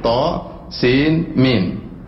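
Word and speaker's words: To, sin, mim (طسم): Oleh katanya, to, sin, mim tidak boleh dibaca, tapi dibacanya per huruf. To, sin, mim.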